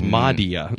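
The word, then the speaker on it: Mahdia: And that's not a funny pronunciation of Maria. It's Madia.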